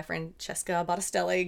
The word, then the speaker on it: Francesca Battistelli: But I was driving to work one day, and I was almost to work, and I had this song by Francesca Battistelli going on in my car. Did I say that right? – You did, but for name and likeness purposes.